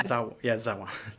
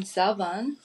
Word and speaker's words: Zawan: Am I pronouncing that right? – Yeah. – Zawan.